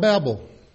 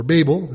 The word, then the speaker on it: Babel: He succeeded the Tower of Babel, or Babel, as some like to pronounce it. I looked it up this morning, and you can say it both ways, apparently.